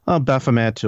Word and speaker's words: Baphomet: everyone had seen recently in, you know, Arkansas, when they trucked over this the statue of, how do you pronounce that, Bohemet? – Baphomet or Baphomet. Most people will say Baphomet, but I mean, the bottom line is the label of Satanism has been thrown around by a lot of people.